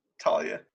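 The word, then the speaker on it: Tua: I wanted to see two quarterbacks, two future Heisman winners, one guaranteed future Heisman winner in Talia. That's how his name's pronounced, right? – Yeah, Talia.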